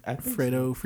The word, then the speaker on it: Fredo: On the topic of hip hop, Fredo Santana, is that how you say his name? – Fredo. Yeah.